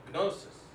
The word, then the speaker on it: gnosis: And the word gnosis, and you pronounce it in the Greek, you pronounce that G, gnosis, means knowledge.